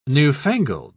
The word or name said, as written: newfangled